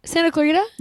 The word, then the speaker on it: Santa Clarita: It's a Bell 206 helicopter in Santa Clarita, California. Did I say that right? – Santa Clarita? – Yeah.